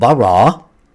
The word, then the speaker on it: bara: It's a Hebrew word, bara, B-A-R-A, and it's pronounced ba and ra, bara.